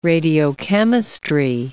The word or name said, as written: radiochemistry